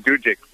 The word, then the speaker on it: Dudzik: Dudzik, yes.